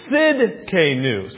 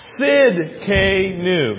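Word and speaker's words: Tsidkenu: It's Sidkenu. So the T is silent. Can you say that with me? Sidkenu.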